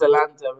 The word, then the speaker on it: Zalando: Is it Zalando? Is that how you say it? – It's Zalando, and they are multi-billion.